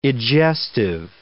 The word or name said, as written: egestive